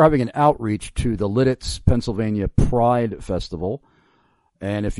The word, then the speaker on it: Lititz: I mispronounced the city. I now understand, according to this listener, it's Lititz. Am I right? Is that Lititz? – You are correct. You nailed it. Lititz, exactly.